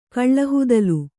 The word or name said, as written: ಕಳ್ಳಹುದಲು